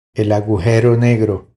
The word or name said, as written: El agujero negro